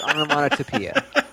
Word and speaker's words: onomatopoeia: I even said it wrong because it's onomatopoeia. There's a T in there.